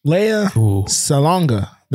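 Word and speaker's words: Lea Salonga: Leia Salgon. Names really trip me up. Leia, I was looking up the pronunciation before I got on here. Leia Salonga. That's, there we go.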